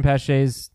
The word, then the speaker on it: Pache: Did I say Colin Pache's Christian Pache's? I didn't say his first name right here. Did I say his name right? Last name. – Well, the last name. Yeah. – Yeah. Okay, good. Could be Paige.